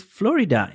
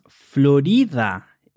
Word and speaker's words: Florida: And you might be wondering if we also pronounce it Florida in Spanish. Well, actually, this adjective is pronounced Florida in Spanish, with the stress in the R, Florida.